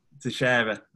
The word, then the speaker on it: Teixeira: Is it Glover Teixeira? Is that right? Or have I butchered his name? – Teixeira, okay.